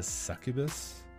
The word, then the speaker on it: succubus: But basically what she is almost like a I want to say, like, not a succubus, but am I pronouncing that right? – Yeah.